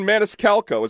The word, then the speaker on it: Maniscalco: I have 2 tickets to see Sebastian Maniscalco.